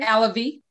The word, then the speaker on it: Alave: And your mom, Alabe, did I pronounce that right? Alabe? – Alavi. – Alavi. Oh, Alavi. Oh, Alavi. – Yes, Alavi. – A-L-A-V-E.